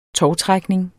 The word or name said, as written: tovtrækning